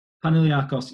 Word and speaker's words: Panathinaikos: Panathinaikos, yes.